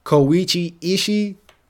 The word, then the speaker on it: Koichi Ishii: Even Elden Ring doesn't make you lose a level from repeated deaths, but according to game director Koichi Ishii, hopefully I'm pronouncing that right, I actually pronounced that wrong. – Hold up. – My bad, I butchered your name. I know how to pronounce your name. Koichi Ishii. Now, Ishii, I might have messed up, but Koichi, that's your name. I know it's Koichi. They deliberately put that, the game director said they deliberately put this mechanic in the game.